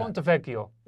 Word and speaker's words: Ponte Vecchio: I went on Ponte Vecchio, however you pronounce that. – Ponte Vecchio.